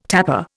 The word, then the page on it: tapper: Indian